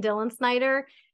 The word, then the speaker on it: Dillensnyder: Well, I'm most active on Instagram, and the challenge will be spelling my last name, but my handle is Kristin Dillensnyder. It's exactly how it sounds.